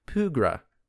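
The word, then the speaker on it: Pwgra: Now, when you face the enemy in the game that is a P-W-R-G-A, you know that that's a Pugra, because the W has an accent over it.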